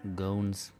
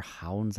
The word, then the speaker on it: Goens: I felt that, in particular, Daniel van Goons, that's how I do it with my American pronunciation, or Hounds, I guess, is the Dutch way of saying it.